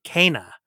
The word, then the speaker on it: Kena: Okay, well, in this game, the main character's name is pronounced K, like in the letter K, Kena.